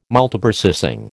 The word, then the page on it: Multiprocessing: American English